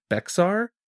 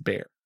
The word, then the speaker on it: Bexar: Immediately, Sergeant Dalton Baker from the Bexar County Sheriff's Department, it's spelled like Bexar. It's not Bexar, so everybody don't jump. Wait, B-E-X-A-R? – Yeah, it's pronounced Bear.